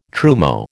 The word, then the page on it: trumeau: American English